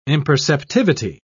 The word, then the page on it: imperceptivity: British English